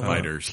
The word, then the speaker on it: FighterZ: Best Fighting Game, Dragon Ball Fighters or FighterZ. I don't know. – Fighters. – Pronounced it Fighters, yeah.